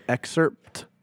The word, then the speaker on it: excerpt: I love how you say the word excerpt. You like actually put the P in it. Most people don't pronounce the P, but like it's good. It's right.